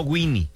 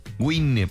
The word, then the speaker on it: Wine: Vayan a Facebook, escriba Baja Wine, se escribe Baja Wine, no Winnie, Wine, Baja Wine.